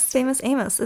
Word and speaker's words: Famous Amos: Wait, is it Famous Amos or Amos? – Famous Amos. It rhymes.